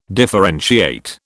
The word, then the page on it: differentiate: British English